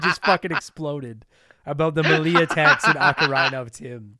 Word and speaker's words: melee: No, melee. I always said melee. I always find it weird. – Yeah, I think melee is the right way to say it. But I used to always say melee, and then someone corrected me, and I was like, well, you say turret.